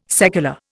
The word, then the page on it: secular: Indian